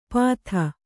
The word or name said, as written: ಪಾಥ